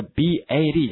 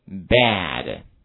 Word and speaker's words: bad: 这个 b a d 这个坏这个词的话，口型就一定要往两边张开了哈，跟我读一下 bad, bad, OK。